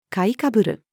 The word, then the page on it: 買いかぶる: female